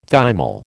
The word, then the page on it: thymol: American English